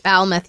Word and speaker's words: Falmouth: Falmouth, yep. All of our... – Why am I from Alabama all of a sudden? – Matt from East Falmouth, Massachusetts.